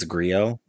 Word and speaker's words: Grillo: Maybe it's Grillo. – I think it's, I've always pronounced the L as the hard L.